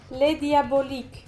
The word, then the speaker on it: Les Diaboliques: Les Diaboliques.